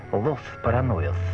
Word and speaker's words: Los Paranoias: Вообще-то, если по-испански правильно произносить, так надо букву «С» шепелявить. «Los Paranoias».